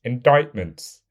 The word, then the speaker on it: indictment: Indictments. It's a silent C. Come on, you've watched enough true crime documentaries and listened to enough true crime podcasts to know how to pronounce indict, in, indictment.